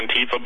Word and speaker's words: Antifa: Thank God their whole Antifa, or is that how you say it? I'm not an expert on the language. – I think that's good, yeah. Antifa, but let's just call them what they are, communists.